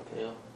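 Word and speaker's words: Thalle: The next bid is from, how do you say that? – Tail. – Tail? Tail, that's T-H-A-L-L-E.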